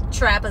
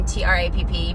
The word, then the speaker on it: Trapp: Trap is the last name, Trapp.